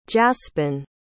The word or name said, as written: jaspen